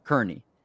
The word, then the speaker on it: Kearney: You go to Nebraska Kearney, if I'm saying that correctly. – Kearney.